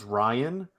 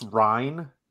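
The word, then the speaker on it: Ryne: It's not pronounced Ryan. It's Rhine, like Rhineland.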